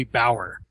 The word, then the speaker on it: Bauer: Her name is Kristen Baier. I'm horrible with pronunciation, you guys know that. B-A-U-E-R. Blonde, fairly attractive. – B-A-U-E-R is probably Bauer. – Bauer? Okay. Well, Kristen Bauer.